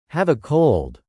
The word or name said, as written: have a cold